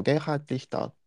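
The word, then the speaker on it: Gerhard Richter: ドイツ語の発音だとゲルハルト・リヒター、リヒターと言います。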